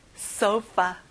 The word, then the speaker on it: sofa: sofa. 그럼 오늘의 단어를 우리 말하기 연습해 볼게요. curtain, curtain, curtain. 식탁, table, table. 소파, sofa. 여기 F 발음 잊지 마세요. sofa.